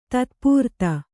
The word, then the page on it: ತತ್ಪೂರ್ತ: tat[pūrta